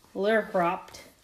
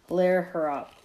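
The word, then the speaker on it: Lehrhaupt: Lehrhoft. I probably said that wrong. – Yeah. – Lehrhoft. Okay.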